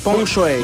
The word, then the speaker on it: Peng Shuai: Escuché notas donde su nombre lo pronuncia como Peng Shuai. – ¿Pong? – Sí. – Por más que se escriba Peng. Shui. – Bueno, sí, Peng Shuai.